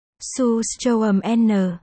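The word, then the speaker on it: surströmming: Sơ Sô Minh. Phát âm. Su Sô Âm N. Tiếng Thụy Điển.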